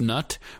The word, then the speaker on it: Knut: She tells us that she agrees it should be pronounced nut, but in the audiobook, he vocalizes it just to make it clear that there's a K on there.